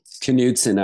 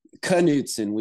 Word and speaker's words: Knudsen: Knudsen, actually, it's a Danish. – Knudsen. – We Knudsen. We pronounce Knudsen. – that K in Danish. It's really weird when you're, an English speaker. But yeah, Knudsen, Edward Lee Knudsen.